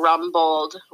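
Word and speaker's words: Rumbold: A lot of people spell it Rumble, but it's rum like the drink. And then Bold, like bold face. So Rum Bold Realtor.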